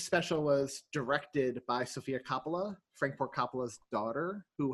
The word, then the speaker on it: Coppola: According to Sofia Coppola's cousin, it's pronounced Coppola.